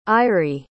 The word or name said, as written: airi